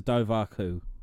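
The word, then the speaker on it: Dobhar-chú: Please correct me if I'm wrong, but Dobhar Chur. – Yeah. – I got it right. – Yeah, see? – Yeah. It's known as the Dobhar Chur, which it is spelled Dobharchu.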